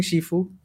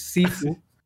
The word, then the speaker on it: Sifu: I'm not mispronouncing Shifu. It is Sifu.